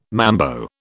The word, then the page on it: mambo: British English